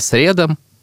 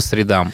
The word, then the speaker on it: средам: И то же самое я говорил, например, раньше всегда «по средам», но mm-hmm. правильно говорить «по средам».